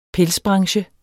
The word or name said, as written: pelsbranche